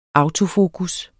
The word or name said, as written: autofokus